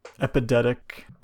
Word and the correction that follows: epideictic: Epidictic. I'm bad at pronouncing. Hold on, I'm gonna Google it. – Epidictic.